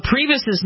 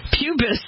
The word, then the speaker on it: Priebus: He pronounced Priebus's name Pubis.